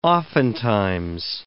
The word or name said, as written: oftentimes